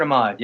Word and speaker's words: Ahmad: Ahmad, yeah, Robert Ahmad, yeah. I might have pronounced that badly, A-H-M-A-D.